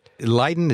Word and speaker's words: Leiden: Leiden, is that the correct pronunciation, Leiden University? – Exactly.